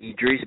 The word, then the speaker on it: Idris: They can locate me at Idris Bolton. It's spelled I-D-R-I-S, last name Bolton, B-O-L-T-O-N.